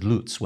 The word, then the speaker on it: Lutz: Did we ever get confirmation on how to say his last name? – Teal Fowler, the scout who found him, says Lutz when he talks about him. So I'm going to go with that because Teal Fowler lives in Germany.